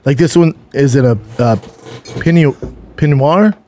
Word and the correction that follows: Pinot Noir: Is that how you say it? – The Pinot Noir.